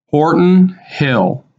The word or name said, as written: Horton Hill